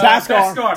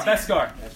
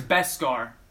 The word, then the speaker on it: beskar: Oh, it's Baskar. – Beskar. It's not Baskar. – I didn't know that. – It's Baskar. – It's Beskar.